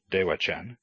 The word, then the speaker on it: Devachan: Devachan is actually a borrowing of the Tibetan word bdebacan, pronounced Devachan, which is the Tibetan translation of Sanskrit Sukhavati, the happy place.